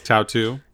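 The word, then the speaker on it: Tautou: Tautu, is that what it was? – Tautu.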